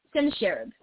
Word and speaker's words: Sennacherib: Sennacherib.